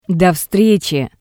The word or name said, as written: Do vstretsi